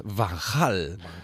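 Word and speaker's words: Van Gaal: Sí, porque la G en Holanda es G, porque es la única, la única herencia que dejamos cuando Holanda era parte del imperio español, es la pronunciación de la G. Eh, por eso es Van Hal.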